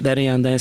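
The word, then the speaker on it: perjantai: Og den berjandai skrives med p, men uttales med b.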